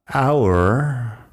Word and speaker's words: hour: Un par de palabras más con la H al principio que no suena. Tenemos honest de honesto, hour de hora, honor de honor.